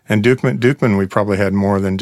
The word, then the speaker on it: Duchman: So, when I see the label, it looks like Dutchman. – It does look like Dutchman. – But it's pronounced Dukeman.